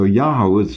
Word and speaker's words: Yahu: as Yahu, Yahu, Yahu. And they don't have the O sound in Assyrian, which you do have in Aramaic. So Yahoo might well be the original pronunciation, actually.